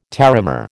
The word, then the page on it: terramare: American English